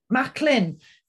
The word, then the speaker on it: Macklin: Am I pronouncing it that correctly, Cara? – Macklin.